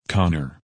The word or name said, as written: Connor